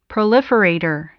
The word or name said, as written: proliferator